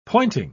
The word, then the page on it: pointing: British English